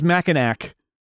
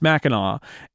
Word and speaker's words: Mackinac: He says Mackinac. And it's not, it's Mackinac.